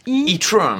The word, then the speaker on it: e-tron: Alors évidemment, on est censé le prononcer à l'anglaise « e-tron », mais ça reste un bon nom de merde quand même, on va pas se mentir.